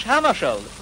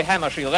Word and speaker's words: Hammarskjöld: When I came here, several people, when I came here, several people asked me how I really do pronounce my name. Well, I pronounce it myself in Swedish, Hammarskjöld. But if you say Hammarskjöld, that's all right with me.